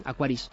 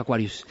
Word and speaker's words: Aquarius: Ahora lo he dicho bien, ¿has visto, Chibi? Lo he dicho bien, Aquarius, ya no he dicho Aquarius.